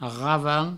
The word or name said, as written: ravan